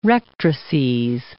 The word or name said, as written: rectrices